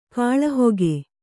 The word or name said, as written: ಗಾಳಿಹಾಕು